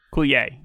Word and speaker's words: Coulier: And by the way, Uncle Joey is actually Dave Coulier. – Dave Coulier. – Coulier. Yeah, that's how you pronounce it.